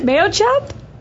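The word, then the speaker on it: Mayochup: And is that how you say it? Mayo chup? – Yep.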